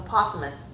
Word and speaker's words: posthumous: was it Pothamus or... – What? – Pothamus entry? – A posthumous. – Posthumous. – Posthumous. – Thank you.